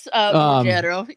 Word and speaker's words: Ruggiero: do you guys think that Ruggiero, or what, how'd you pronounce it? Ruggiero, whatever you said. – Ruggiero, yeah.